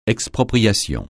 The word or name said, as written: expropriation